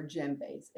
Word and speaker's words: djembe: We are using, use mostly are djembes. It's spelled D-J-E-M-B-E. You don't pronounce the D.